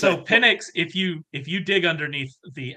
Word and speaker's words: Penix: I believe it's Penix. – Yeah, Penix.